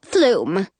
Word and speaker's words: Flume: Flume. Rhymes with room, Jonah says. And loom, the guy says.